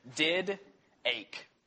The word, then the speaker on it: Didache: And it's did-ache is how you spell it. So D-I-D-A-C-H-E.